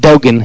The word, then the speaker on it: Dogan: His last name is spelled D-O-G-A-N. It's Lieutenant Dogan, and he's with the U.S.